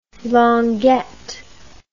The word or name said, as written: longuette